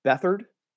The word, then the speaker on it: Beathard: Anyway, CJ Beathard, I believe is how you say his name.